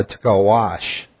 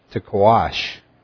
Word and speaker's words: taqsh: But it's called a tkawash. Tkawash. It's spelled T-A-Q-S-H.